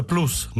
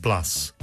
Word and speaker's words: plus: Perciò plus si pronuncia plus, non plus. Media si dice media, non media. Junior si pronuncia junior, non junior. Se il fidanzato insiste affinché diciate junior, non cambiate pronuncia, cambiate fidanzato.